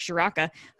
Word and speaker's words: Ciarrocca: Um, welcome to welcome to, to, State College, uh, uh, Kirk Shiroka. Um, Shiraka, sorry, um, I still have to learn how to say his name, Kirk Ciarrocca, who was the former offensive coordinator at Minnesota.